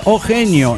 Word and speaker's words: Ojje: Se pronuncia Ojo, como Ojo. Ah, Oje. ¿Sabe lo que pasa? Ajá. Que porque usted, pirata, póngale, estás rependejo. Pero eso se escribe O-J-J-E.